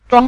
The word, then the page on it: zhuang: zhuang5